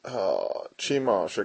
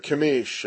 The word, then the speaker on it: Chemosh: He shall come, he's going to be going up and seeking, because Moab, I believe they followed Chemosh, or Chemish, I think is actually the pronunciation.